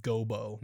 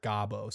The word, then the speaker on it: Gobo: Yeah, just so you guys know, that's how it's pronounced. Gobo, not Gabo.